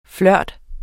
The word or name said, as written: flirt